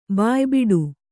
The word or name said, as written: ಬಾಯಿಬಿಡು